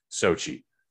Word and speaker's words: Xochitl: Uh, I think a lot of folks are going to wish there was more America Chavez, played by Xochitl Gomez, which isn't spelled phonetically Sochi. I, I can't don't ask me to spell it. And I was thankful enough to see enough YouTube videos that pronounced it Sochi.